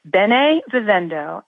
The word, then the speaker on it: Bene Vivendo: It's Bene, B-E-N-E, dot, Vivendo, V as in Victor, I-V-E-N-D-O. Bene Vivendo, and people are more than welcome to send me messages on Instagram at Bene Vivendo.